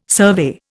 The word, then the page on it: survey: Indian